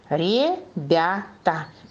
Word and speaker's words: ребята: Ребята. Посмотрите и скажите, куда стоит ударение, куда она падает? На слог «бя», на гласную «я».